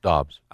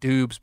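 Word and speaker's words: Doubs: I think they pronounce his name Dobbs, by the way. – It is. It's Dobbs. – I like Dubes better, though. – Yeah, but it's not. It's Dobbs.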